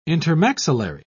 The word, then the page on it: intermaxillary: British English